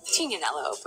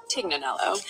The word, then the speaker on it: Tignanello: The name the Tig comes from a wine called Tignanello, but oftentimes it's mispronounced Tignanello.